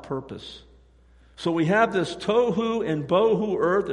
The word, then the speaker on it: tohu: Formless in Hebrew is the word tohu, T-O-H-U, tohu, and it's defined as chaotic or lacking structure. Listen, there's no coincidence. If tohu means chaotic and lacking structure, there's absolutely no coincidence that tohu rhymes with tofu.